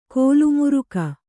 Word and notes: ಕಾಲಮುರಿಗೆ: kālamurige

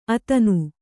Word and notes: ಅತನು: atanu

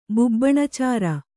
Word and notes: ಬುಬ್ಬುಣಾಚಾರ: bubbuṇācāra

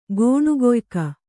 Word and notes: ಗೋಣುಗೊಯ್ಕ: Kannada

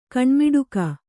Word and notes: ಕಣ್ಮಿಡುಕ: kannada; kaṇmiḍuka

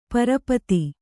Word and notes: ಪರಪತಿ: Kannada

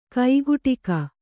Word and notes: ಕೈಗಡಿಕ: kaigaḍika

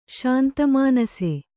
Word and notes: ಶಾಂತಮಾನಸ: Kannada

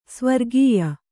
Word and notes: ಸ್ವರಗೆಯ್: Kannada

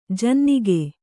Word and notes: ಜನ್ನಿಗ: janniga

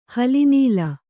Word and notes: ಹಲಿನೀಲ: hali nīla

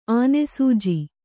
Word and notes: ಆನೆಸೂಜಿ: Kannada

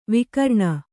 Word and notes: ವಿಕಿರಣ: vikiraṇa

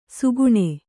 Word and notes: ಸುಗುಣೆ: suguṇe

